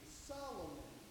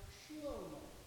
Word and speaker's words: Shlomo: But the way that we say his name, Solomon, is taken from the Greek. The Hebrew pronunciation is not Solomon, but rather Shlomo, which